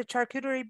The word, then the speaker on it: charcuterie: So there's, or learn to cook or do the charcuterie board. Did I say it right? – Yeah, charcuterie. – You did.